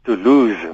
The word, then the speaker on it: Toulouse: Jy spreek hom wel uit. Toulouse, mense sê Toulouse met eintlik met 'n Z uh, as 'n uitspraak omdat die die e wat aan die einde van 'n s kom dit die, die effek het dat dat die voorafgaande s as stemhebend uitgespreek word byvoorbeeld die woord denis die,